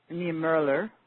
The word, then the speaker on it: Niemöller: Those particular Protestant sects that I mentioned, and the ones with Niemöller and Niemöller, is pronounced,